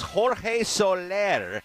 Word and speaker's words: Jorge Soler: Jorge Soler. Am I pronouncing that right, Deborah Green? – Yes.